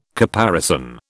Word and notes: caparison: British English

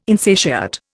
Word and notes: insatiate: Indian

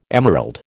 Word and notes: emerald: American English

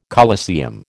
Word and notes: coliseum: American English

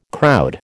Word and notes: crowd: American English

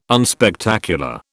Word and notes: unspectacular: British English

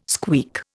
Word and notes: squeak: Indian

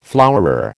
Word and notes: flowerer: American English

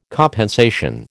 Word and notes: compensation: American English

